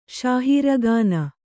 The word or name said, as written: ಶಾಹೀರಗಾನ